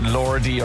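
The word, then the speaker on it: Lorde: Yeah, I wasn't sure at the start how to pronounce her name. Was it Lordy or Lord? Lordy were the Finnish Eurovision lads, but yes. Do you know what? Yes. This is Lord.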